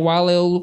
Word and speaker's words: Wale: And follow me on Facebook at Wale Olukayede, if you can't spell that. W-A-L-E. last name is O-L-U-K-A-Y-O-D-E.